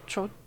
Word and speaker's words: trottoir: And it's, how do you say trottoir? – Trottoir.